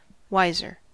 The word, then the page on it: wiser: American English